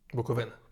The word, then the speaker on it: Буковина: або Буковина. Буковина. Буковина, перепрошую. Буковина. Я знаю. З наголосом має вічну проблему.